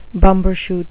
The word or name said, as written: bumbershoot